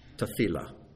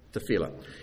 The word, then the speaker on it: tefillah: And it's pronounced tefillah. Okay? Tefillah.